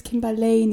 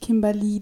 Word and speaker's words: Kimberley: Mein Name ist Kimberley. Kim reicht, weil mein Name wird eh 90% der Fälle falsch ausgesprochen. Es ist Kimberley, nicht Kimberly. Dankeschön. Auch liebe Dozenten, da ist ein E.